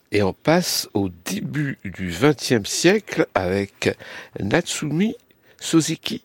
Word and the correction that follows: Natsume Soseki: si je prononce bien. – Natsume Soseki.